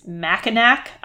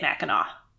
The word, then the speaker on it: Mackinac: Chris rang in and said, what is Mackinac Island? Which you would think that's how it's pronounced because it does end with a C, but they say it Mackinac.